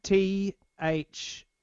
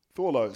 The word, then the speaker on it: Thorlos: It's Thorlos. – Thorlos. So they're like a legendary... – Thor, the superhero, and then Loss. Lost without a T.